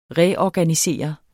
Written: reorganisere

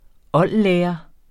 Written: oldlærer

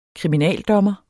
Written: KRIMINALDOMMERE

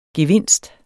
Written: gevinst

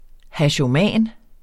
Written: hashoman

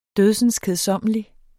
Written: dødsenskedsommelig

